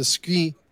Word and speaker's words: Skrei: So, Chef, I have to ask you about the Skrei. I got to say it like that. Sorry.